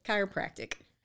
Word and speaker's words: chiropractic: Did I say it right? Chiropracticism? Is that right? – Almost. – How do you pronounce it? – Chiropractic. – Oh, it's just chiropractic.